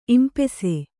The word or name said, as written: ಇಂಪೆಸೆ